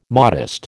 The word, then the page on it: modest: American English